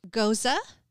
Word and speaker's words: Gose: Goza, which is G-O-S-E, but it's pronounced Goza.